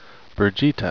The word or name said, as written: birgitte